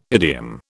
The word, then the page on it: idiom: British English